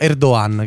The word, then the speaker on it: Erdogan: Ecco, innanzitutto si pronuncia Erdogan, che la G è muta, primo.